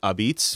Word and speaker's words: apizza: They can call it Abiz, which is how we spell it. Say it is A-P-I-Z-Z-A. – Abiz. – That's the dialect from Naples from 150 years ago.